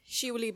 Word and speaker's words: Shivali: So, I like to introduce myself as Shivali Banerjee. That's three syllables, but often pronounced as two, so people call me Shuli, and that's cool too.